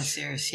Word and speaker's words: Osiris: of Osiris. Is that how you say it? – The Egyptian god Osiris, yeah.